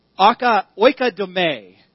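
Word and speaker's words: oikodome (οἰκοδομή): One of the words in 1 Corinthians 14 is this Greek word, oikodome. The emphasis is on the last syllable, which I hate.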